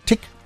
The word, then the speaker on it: Tich: Dave Dozy, Breaky Mick und Tick. Hideaway. Ich muss immer aufpassen hinten, dass ich das ordentlich ausspreche. Ne? T-I-C-H wird Tick ausgesprochen. Ja, so wie Tick-Tack.